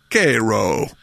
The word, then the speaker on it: Cairo: The city in Georgia that is spelled C-A-I-R-O is Cairo. Cairo.